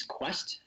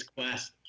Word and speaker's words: Kwest: And my startup's name is Quest. It's spelled K-W-E-S-T. So it is not Kanye West or Key West. Hey West, it is Quest.